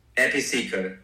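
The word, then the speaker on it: ehipassiko: And, and, like you say, I, I never pronounce it Episaco, I think, you know, come and see for yourself, right? Episaco. Um, Episaco.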